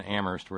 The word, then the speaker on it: Amherst: Let me check in with Kitty in Boston and Terry in Amherst, where only the H is silent.